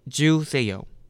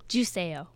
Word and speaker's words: 주세요: It's not too bad, but there's like really easy way to pronounce it. – Yeah. – You know what juice is? – Yes. – And then ayo? – Juiceo. – Juiceo. – That's what it sounds like? – Yep.